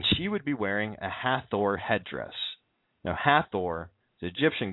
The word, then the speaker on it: Hathor: But her name is Hathor. Ha-Thor. You know, you can find our words like author also containing the god Thor. Let me think here. Oh, yes. Canaan. It's spelled C-A-N-A-A-N.